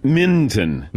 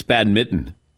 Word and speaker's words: badminton: Minton. – Minton, isn't it? – But we don't pronounce the N. It's badminton.